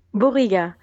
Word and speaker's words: Bouriga: Bouriga.